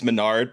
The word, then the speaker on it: Mesnard: I'm in the same boat because my last name is Menard, but it's spelled Mesnard. And so I am constantly slaughtering my last name too.